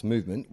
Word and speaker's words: MVMT: Introduction, MVMT Watches, pronounced Movement, was founded on the belief that style shouldn't break the bank.